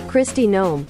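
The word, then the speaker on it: Kristi Noem: I got the pronunciation right. Did I? Let me double check. Otherwise, I might have to edit this or look stupid. Say, all right. Now pronounce. Where's our Pronouncify lady? Pronounce. There's our Pronouncify lady. – Christy Gnome. – Yeah, I got it right.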